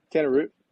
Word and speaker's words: Tanner Root: Yeah, Tanner Root.